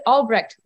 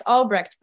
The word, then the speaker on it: Albrecht: Well, I pronounce it Albrecht, Lex Albrecht, but that's not exactly how you pronounce it. It's German.